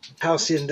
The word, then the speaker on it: Halcyon: Right on, the Silver Beats and Girlfriend off their new album, and it's called like, I'm not sure how you quite pronounce it, but it's like High Lacan, I believe. – Halcyon Days. – Halcyon Days, thank you. Sorry. – I think that's how you say it, yeah.